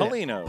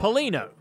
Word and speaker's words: Polino: This time, it was Steve How do you even say Polino. – That? Polino. Steve Polino. That O is silent.